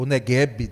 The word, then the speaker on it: Negev: colocamos um vídeo sobre o deserto do Negev, ou Negev, né, também tem essa pronúncia.